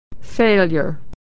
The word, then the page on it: failure: y